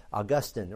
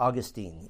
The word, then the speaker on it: Augustine: Now, Pelagius had an arch enemy in doctrine, and this was a guy by the name of Augustine, or Augustine. Either pronunciation is correct.